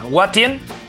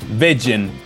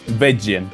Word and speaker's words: Watjen: ¿Cuál es la correcta pronunciación? ¿Watien? – Beggen. – Así, tal cual. O sea, W-A-T-J-E-N. – Exacto. Beggen.